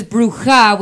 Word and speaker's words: Bruja: But the reason that that's important is because my name is Bruja with the J pronounced differently.